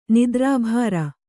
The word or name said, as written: ನಿದ್ರಾಭಾರ